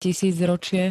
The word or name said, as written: tisícročie